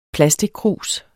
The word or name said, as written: plastickrus